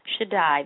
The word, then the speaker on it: Shaddai: In Hebrew, Almighty is named Shaddai, which, as a matter of interest, actually pronounced Shaddai.